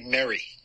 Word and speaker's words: Mary: And then two, do you pronounce the character in the book's name Mary or Marie? – Actually, Mary. – Mary? – Yeah, like Merry Christmas.